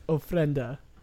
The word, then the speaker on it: ofrenda: It's pronounced ofrenda. – Okay. – It's Spanish for an offering.